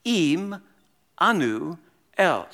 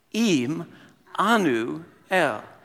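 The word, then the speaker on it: Im, Anu, El (עמנו אל): You pronounce it this way. Im, Anu, El. So you read from right to left. So let's all read Hebrew together. Ready? Here we go. Im, Anu, El.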